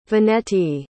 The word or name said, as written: veneti